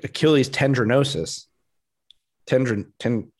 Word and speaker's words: tendinosis: Tendinosis. Tendinosis. First syllable for sure. Um, sounds like sounds like dinner.